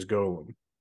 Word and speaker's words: golem: Yeah, the proper the proper Hebraic pronunciation is golem.